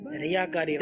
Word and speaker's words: ریاکاری: ریاکاری. – جواب انہوں نے بھی درست دے دیا ہے. غلطی کرتے ہیں لوگ, ریاکاری نہیں ہے, یہ لفظ ریاکاری ہے.